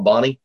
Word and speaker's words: Bona: If you're from here, they call it Bonnie, but it's spelled B-O-N-A.